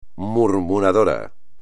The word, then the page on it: murmurador: espagnol